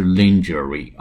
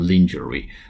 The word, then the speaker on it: lingerie: 提供三类 T I E R 三个 Tiers T I E R 三个类别的这个成人清洗服务 Adult Cleaning 成人给供成人来使用的这么一个清洗服务，With a service in lingerie L I N G E R I E 这个词呢是。呃，女用内衣、贴身内衣的意思啊，但是呢，它这个发音比较奇怪，叫 lingerie，lingerie，美音叫 lingerie，哎，l i n g e r i e，不是 lingerie 啊，字面容易发成 lingerie，实际上叫 lingerie，英音美音叫 lingerie，啊，就是女性内衣的意思。